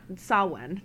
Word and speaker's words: Samhain: That's Or not, Samhain. That's how you pronounce it. – Oh, is it really? – Yeah. – Oh. – It's spelled Samhain, but it's pronounced Samhain.